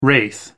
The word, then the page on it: wraith: American English